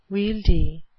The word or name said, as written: wieldy